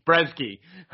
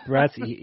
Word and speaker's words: Brzeski: Dylan Potter, Lukasz Brzeski. something funny. – Your pronunciation of Polish names is always gets me. It's Brzezki. – Brzezki.